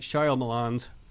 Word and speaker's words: Shyamalan: Night Shyamalan's. Did I pronounce that right? – Shyamalan, yeah.